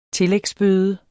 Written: tillægsbøde

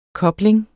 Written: kobling

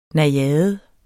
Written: NAJADEN

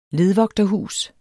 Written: ledvogterhus

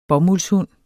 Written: bomuldshund

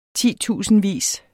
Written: titusindvis

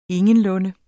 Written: ingenlunde

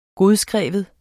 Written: godskrive

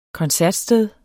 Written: koncertsted

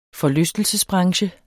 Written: forlystelsesbranche

